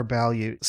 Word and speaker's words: ballute: First up in the news here, SpaceX wants to use a, say it with me now, Balyut to recover stage 2. Did I say that right? – Yeah, Balyut or Balyut. So it's a portmanteau of balloon and parachute. So I say Balyut.